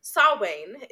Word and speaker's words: Samhain: Samhain. It's not spelled like that at all,